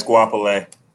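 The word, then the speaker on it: Goapele: Guapoli. I might not be pronouncing her name right, but Guapoli. G-O-A. – You saying it right. It's Guapole.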